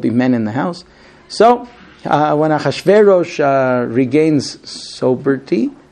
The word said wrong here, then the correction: sobriety: that's how you pronounce it? – Sobriety. – Sobriety, okay.